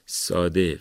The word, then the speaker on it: ساده: پس ساده فارسیه، تشدید نداره و ما واقعا تاکید نکنیم که این کلمه رو با تشدید بخونن.